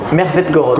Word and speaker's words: Mertvetgorod: Mertvetgorod. Comme c'est du russe, en fait, toutes les lettres se, prononcent.